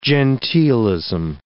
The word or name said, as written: genteelism